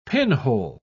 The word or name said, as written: pinhole